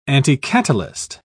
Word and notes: anticatalyst: British English

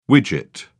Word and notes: widget: British English

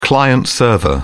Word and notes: client-server: British English

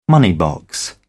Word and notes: money box: British English

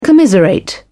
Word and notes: commiserate: British English

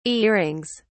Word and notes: earrings: British English

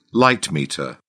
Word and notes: light meter: British English

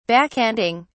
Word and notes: backhanding: British English

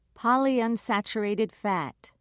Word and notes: polyunsaturated fat: British English

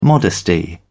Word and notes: modesty: British English

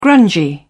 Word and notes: grungy: British English

